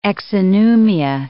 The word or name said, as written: exonumia